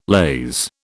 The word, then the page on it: laze: British English